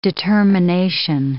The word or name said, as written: determination